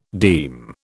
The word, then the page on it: deme: British English